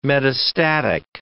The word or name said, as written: metastatic